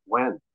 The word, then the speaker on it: Nguyen: And it's like, no, it, it's it's pronounced Nguyen.